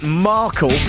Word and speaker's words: Markle: But also at the good fortune that Markle rhymes with sparkle.